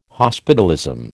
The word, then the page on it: hospitalism: American English